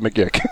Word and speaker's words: magick: We have to deal with M-A-G-I-C-K as the standard word, and anything else is a deviation. – This character pronounces it McGick.